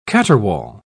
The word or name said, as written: caterwaul